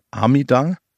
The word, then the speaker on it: Amida: Der Name Amida ist die japanische Aussprache des Sanskritwortes Amita.